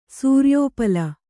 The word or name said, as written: ಸೂರ್ಯೋಪಲ